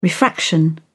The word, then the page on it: refraction: British English